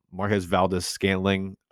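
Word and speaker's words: Marquez Valdes-Scantling: Have you seen all the shit on social media about Marquez Valdez-Scantling Is that how you say his name? I don't even fucking. – Yeah, MVD.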